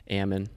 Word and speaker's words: Ammon: Ammon. – Ammon. – Like okay. – Salmon, but without the S.